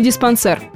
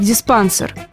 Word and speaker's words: диспансер: Чтобы понять, как будет правильно, давайте вспомним, что имеем дело с французским языком, в котором все более-менее однозначно и ударение падает на последний слог. И так уж сложилось. Из французского чаще всего ударение сохраняется и в русском. Так что прочь все сомнения. Будет однозначно диспансер.